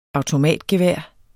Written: automatgevær

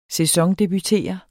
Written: sæsondebutere

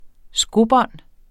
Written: skobånd

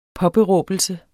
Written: påberåbelse

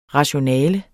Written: rationale

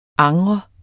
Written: angre

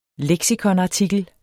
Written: leksikonartikel

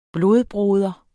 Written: blodbroder

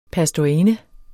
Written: pastorinde

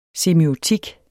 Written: semiotik

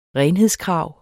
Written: renhedskrav

